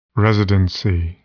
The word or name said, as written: residency